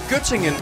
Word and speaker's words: Göttingen: Var det ved Gødringen? – Nej, Göttingen.